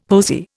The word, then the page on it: posy: Indian